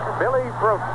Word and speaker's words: Bruton: I said it right today, Joe. Billy Bruton.